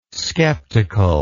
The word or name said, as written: sceptical